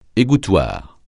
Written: égouttoir

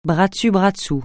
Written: bras dessus bras dessous

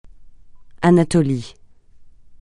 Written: Anatolie

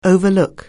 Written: overlook

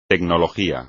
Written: tecnología

